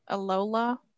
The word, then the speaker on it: Alola: Did I Alola. – Say that right? Alola.